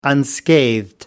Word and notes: unscathed: American English

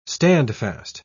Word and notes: standfast: British English

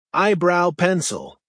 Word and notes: eyebrow pencil: American English

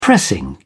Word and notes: pressing: British English

